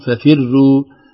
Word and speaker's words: ففروا: ففروا، البعض يقول ففروا ففروا، كسرة الفاء قبل الراء تؤثر على نطقها إن لم ننتبه، فننتبه لففروا تفخيم الراء، وكذلك ساحر وليس ساحر، الترقيق في الراء يعني يسير جدا وأسهل في النطق، فينبغي علينا أن ننتبه لذلك، فلا ترقق ففروا ولا ترقق ساحر.